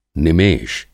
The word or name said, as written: निमेष